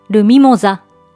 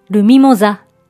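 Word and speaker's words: mimosa: ちなみに、先ほど挙げた 3 つの黄色い花、フランス語名をご存知でしょうかミモザは男性名詞で、発音は同じ。ルミモザルミモザと言います。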